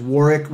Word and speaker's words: Warwick: You can engage us on LinkedIn at Warwick Fairfax, and that is Warwick with the silent W in the middle, W-A-R-W-I-C-K, at Warwick Fairfax.